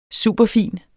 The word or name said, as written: superfin